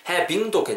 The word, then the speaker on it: having: having도 괜찮겠지만 기왕이면 having v 사운드는 윗입술 아랫술이 닿으면 안 됩니다. 윗니가 아랫술이 되시고 긁으면서 having, they're having, they're having 이렇게 발음해 주시면 좋을 것 같아요.